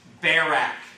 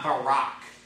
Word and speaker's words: Barak: And yes, that's how it's pronounced. Barak, not Barak.